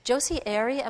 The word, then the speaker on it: Josie Airey: And this was Josie Airey, am I yes. – saying that correctly? – That's right, you are.